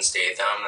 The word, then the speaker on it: Statham: Statham is spelled like Jason Statham, that famous kick-assing actor. And when he got popular, like, people started pronouncing my name a little more correctly.